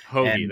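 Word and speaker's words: Hoge: Give me a little fairway finder in Tom Hogue. – Oh, Tom Hoagie. I like him. I'll discuss him later on in the podcast, so we'll come back to that later. – Hoagie, that's right. I butchered it already.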